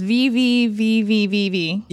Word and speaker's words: VVVVVV: V V V V V V.